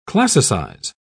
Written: classicize

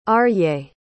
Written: Arye

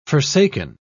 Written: forsaken

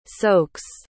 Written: soaks

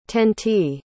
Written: tenty

